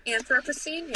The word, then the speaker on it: Anthropocene: Is that how you pronounce that? Yeah. – Anthropocene, yeah.